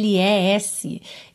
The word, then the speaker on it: miracles: Então, haveria aí uma tendência, né, se a gente fosse ler como se lê em português, miracles, né, miracles. Não, a gente pensa em call mesmo, tá? Como se fosse CL, pode inverter aí o E e o L de posição só no seu pensamento, tá? Para você pegar essa pronúncia.